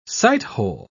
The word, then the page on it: sighthole: British English